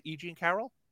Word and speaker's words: E. Jean Carroll: E. Jean Carroll.